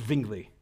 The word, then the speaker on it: Zwingli: You gotta give it that ZV. Sort of, it's actually a W, but you got to pronounce it Zwingli.